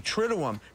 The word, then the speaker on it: triduum: That word that Keegan spelled, pronounced so correctly, triduum, comes from the Latin meaning what? – 3 days.